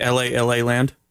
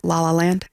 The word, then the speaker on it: La La Land: Did you see L.A. Land? – No. – Is that how you pronounce it? – La La Land. – Oh, that makes more sense.